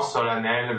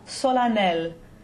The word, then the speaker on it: solennel: That second syllable of that is not what we expect it to be, right? – Yes. Yeah, it looks like it should be solennel. I guess you could call it an exception.